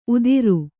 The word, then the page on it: ಉದಿರು: Kannada